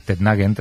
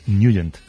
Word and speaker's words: Nugent: Siempre lo hemos llamado en el barrio Tednagen o Tednagen. – Claro, yo era de Tednagen. – Pero la pronunciación correcta es Nugent.